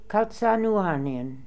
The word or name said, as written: Kalzanooarnian